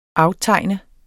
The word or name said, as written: aftegn